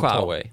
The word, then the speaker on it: Huawei: Eh, lite kort kan vi göra Huawei. Jag har alltid ja. – velat eh, att folk ska höra mitt uttal. Huawei.